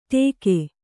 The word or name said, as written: ಟೇಕೆ